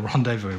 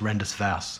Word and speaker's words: rendezvous: You know, that's when the rendezvous went on to Scarborough Beach. I've heard it pronounced Rendus Vass, which is always great.